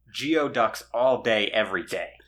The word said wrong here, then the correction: geoduck: Gooey ducks. It's pronounced gooey ducks.